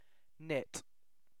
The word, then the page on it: knit: British English